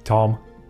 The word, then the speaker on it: Thom: Thom. – Ah. Oh, yeah. – With a TH.